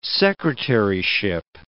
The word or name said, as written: secretaryship